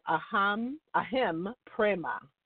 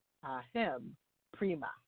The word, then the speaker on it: Aham Prema: Ahem Prema. Okay, so that's spelled A-H-A-M and then P-R-E-M-A. That's two words.